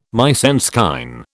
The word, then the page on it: mise en scène: British English